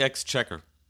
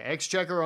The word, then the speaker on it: Exchequer: How do you say that? – I think it's the Exchequer. – Okay, Exchequer on the Supreme Economic Council.